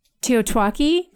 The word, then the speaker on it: TEOTWAKI: What I read made sense, and now I do what I can, not for Teotwaki, did I say that right? – Yes, which means the end of the world as we know it.